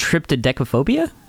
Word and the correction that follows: triskaidekaphobia: No. – Did I get it right? No. – It's... Triskaidekaphobia.